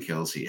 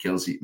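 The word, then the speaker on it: Kelce: Han har, eller Kels, som det udtales, det fandt vi først ud af i år, efter han har spillet i over 10 år, fandt vi ud af, at hans efternavn udtales forresten ikke Kelsey, men Kels.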